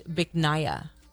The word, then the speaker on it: Bich Nga: So I can't say this on the air, but how it's spelled is B-I-C-H-N-G-A. Okay, so I'll let your imagination run away with that. But it's pronounced Biknaya, okay, which loosely translates to beautiful jade.